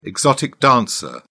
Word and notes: exotic dancer: British English

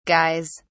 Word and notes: guys: British English

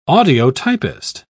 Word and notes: audiotypist: British English

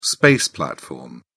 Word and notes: space platform: British English

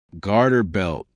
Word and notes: garter belt: American English

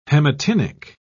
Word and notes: haematinic: British English

